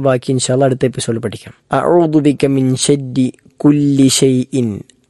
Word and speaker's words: أعوذ: ഒന്ന് അഊദു, അഊദു, അത് ശ്രദ്ധിക്കേണ്ടതായ 3 ഒന്നാമത്തെ അതെല്ലാവരും ശരിയാക്കാറുള്ളതാണ്, ആർക്കും തെറ്റില്ലാത്ത ഒരു കാര്യമാണ്, അക്ഷരമാണ്. രണ്ടാമത്തേത് ഐനാണ്, അഊ, അഊ എന്ന ഐൻ തൊണ്ടയുടെ മധ്യഭാഗത്ത് നിന്നും പുറപ്പെടേണ്ട അക്ഷരമാണ്.